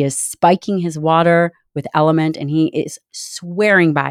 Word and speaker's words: LMNT: I'm super grateful for Element for sponsoring this episode of the Dr. Gabrielle Lyon Show. It is spelled L-M-N-T and pronounced Element.